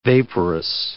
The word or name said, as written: vaporous